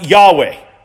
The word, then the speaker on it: Yahweh: And we know that the best we can do at pronouncing this, we don't know exactly how the ancient Hebrews pronounced it. Modern Jews, for the longest time, have refused to pronounce the name. But we believe it's pronounced Yahweh. That's the closest we might come in English.